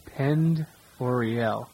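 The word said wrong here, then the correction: Pend Oreille: It looks like it's P-E-N-D, and that's pronounced pond. – Pond. – And then, so it's Pondere, even though it looks like Pandorielli.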